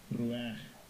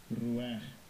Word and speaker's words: Ruach: Ruach. Ruach.